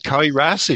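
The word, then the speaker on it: Cae Ras: call it Kai Ras if you want to do the Welsh version.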